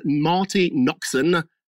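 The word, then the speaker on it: Marti Noxon: Also, too, just a quick add-on here, a producer from the show, a lady called Marty Noxon, N-O-X-O-N, Marty Noxon, that's how you pronounce it, is claiming there's also some actual male actors on the show who are not very nice either.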